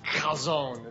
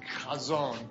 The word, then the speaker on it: chazown: It's spelled C-H-A-Z-O-W-N. If you ever want to say it, what you have to do is you have to act like you're clearing your throat, like you're about to hack a loogie or something.